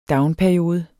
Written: downperiode

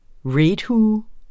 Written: raidhue

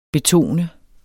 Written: betone

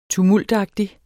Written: tumultagtig

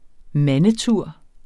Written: mandetur

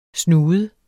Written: snude

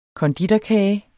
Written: konditorkage

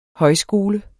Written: højskole

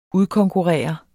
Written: udkonkurrere

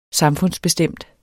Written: samfundsbestemt

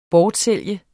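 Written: BORTSÆLGES

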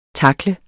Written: tackle

